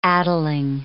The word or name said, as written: addling